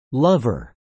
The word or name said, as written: lover